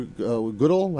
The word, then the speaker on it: Goodell: I read they paid Roger Goodell. How do you pronounce Goodell? – Goodell $44.5 million last year.